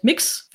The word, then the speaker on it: Mx: Also statt Mr. oder Mrs. sagt man halt Mix, wird also ausgesprochen und wird halt MX geschrieben.